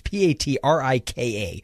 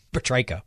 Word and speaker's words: Patrika: It looks like it should be Patricia, but it's according to IMDb, it's Patrika. So I'm going to go with Patrika, whatever.